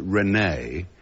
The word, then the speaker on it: René: So I can't tell you how warmly I welcome this opportunity of explaining the correct version, if only for poor Rini's sake. Rini being, as you know, the unfortunate Christian name of this eminent 17th century philosopher. And even though the French pronounce it René, It was still one hell of a liability for a chap in those rough times.